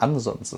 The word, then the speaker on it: Ansons: Weil alle immer Ansons oder so sagen, aber Ansons ist korrekt.